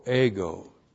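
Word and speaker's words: ego (ἐγώ): E-G long O, ego.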